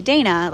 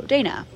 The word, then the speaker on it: Dayna: Like, they would see Dana, like, D-A-Y-N-A, and be like, oh, Dana. But no, people usually never say my name right. They usually say, like, a variation of, like, Diana, Dinah, Dina, some other weird ones I've heard too. But it's like, they never really quite say Dana.